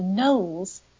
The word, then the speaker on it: Knollys: Now, Charlotte Knowles, and the Knowles is pronounced Knowles, but spelt K-N-O-L-L-Y-S.